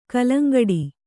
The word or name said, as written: ಗೆಲ್ಲಂಗುಡು